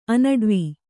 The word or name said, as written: ಅನಡ್ವ